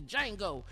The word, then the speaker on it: Django: And the D is silent on Django.